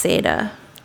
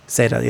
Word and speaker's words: Seda: Actually, I think it's pronounced Seda. – Seda, yeah, that was the Spanish folk. – Yeah, Latin. John Seda.